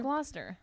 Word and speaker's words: Gloucester: It's Gloucester. – It looks like there should be. It looks like Worcestershire.